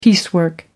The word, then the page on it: piecework: American English